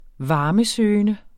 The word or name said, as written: varmesøgende